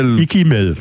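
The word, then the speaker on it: Ikkimel: Ach du, da legen wir doch mal gleich richtig los mit der Ikimel, mit kein Kokain, oder? – Ikimel, so spricht man es aus, okay.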